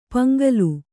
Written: ಪೊಂಗಲ್

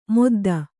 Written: ಮುದ್ದ

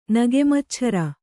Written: ನಗೆಮಚ್ಛರ

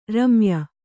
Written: ರಮ್ಯ

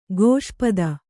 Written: ಕ್ಷಿಪ್ತ

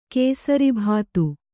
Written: ಕೇಸರಿಭಾತು

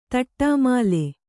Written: ತಟ್ಟಾಮಾಲೆ

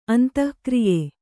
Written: ಅಂತಃಕ್ರಿಯೆ

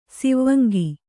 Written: ಸಿವಂಗಿ

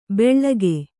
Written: ಬೆಳ್ಳಗೆ

